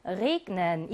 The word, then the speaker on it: regnen: 그 다음 비가 내리다 라고 하는 동사의 G도 자음 앞에 G가 나와 있지만 G로 발음해서 Regnen 이라고 해요.